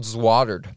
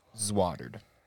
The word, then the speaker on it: Zwoddered: It's called Zwattered. The word is Zwattered. – Spell it for me, please. – Hold on. I'm getting the notes up. It is Z-W-O-D-D-E-R-E-D. – Zwattered.